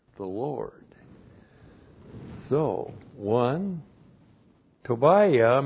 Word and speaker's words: Tobiah: Actually, you pronounce it Tobiah. That's T long O B I Y A H. That's how it looks like in the Hebrew. In the English, you have Tobijah.